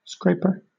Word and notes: scraper: British English

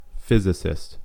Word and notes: physicist: American English

